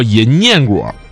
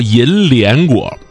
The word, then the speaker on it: 银念果: 叫银念果啊，也有叫银莲果，这应该就是叫着叫着音变了，那念呢念成莲了。